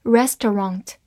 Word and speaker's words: restaurant: R E S T A U R A N T，restaurant，R E S 发 res，中间 A U 在这里省略了发音，所以 T 和 R 合成为 tr 这个音，A N T ont，restaurant，restaurant，美式发音 A N T 的口型比较大，restaurant 或者读作 restaurant，它是一个名词，表示餐馆，比如说。